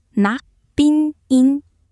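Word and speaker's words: Nak Bin In (นักบินหญิง): 好，我们再复习一次，女飞行员，泰语是 Nak Bin Ying，发音是 Nak Bin In，学会了吗？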